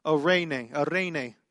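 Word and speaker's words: eirene: See, Arene, you have to kind of roll your R's. Arene is the Greek there.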